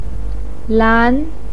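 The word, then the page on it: lan: tone 2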